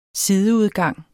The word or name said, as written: sideudgang